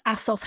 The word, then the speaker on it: ASOS: ASOS. How do you say that? – ASOS. – Because it's ASOS, yeah. – As seen on screen. – Holy shit.